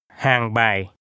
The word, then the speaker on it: Hàng Bài: Now imagine you're in Hanoi and you're looking for directions, here's how to say Hàng bài. – Street. Listen and repeat. – Phố hàng bài. Bài. Hàng. Hàng bài. – The street's name is pronounced with two low falling tones.